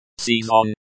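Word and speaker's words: Sezon (сезон): Sezon, with the stress on the second syllable.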